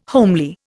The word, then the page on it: homely: Indian